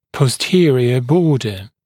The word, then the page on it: posterior border: pɔs’tɪərɪə ‘bɔːdə; пос’тиэриэ ‘бо:дэ